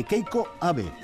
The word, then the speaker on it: Keiko Abe: Este es un fragmento de Prince Rhapsody para Marimba, de Keiko Abe. ¿Lo he dicho bien? – Lo has dicho muy bien.